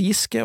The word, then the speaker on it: Giske: Trond Giske-filmen var det i sporet om på spørsmål fire, og ja, han uttales Giske fordi eh, øya uttales Giske, og da uttales han Giske. Ikke Giske, som mange vil hevde,